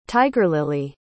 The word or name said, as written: tigerlily